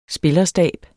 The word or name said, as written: spillerstab